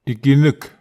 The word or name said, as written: iken ek